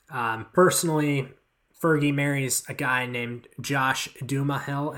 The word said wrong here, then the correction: Duhamel: Am I pronouncing that right, Anthony? – No, no, no. Josh Duhamel. It's just it, it's just Josh Dumel.